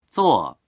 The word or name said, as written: zuo4